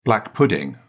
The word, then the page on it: black pudding: British English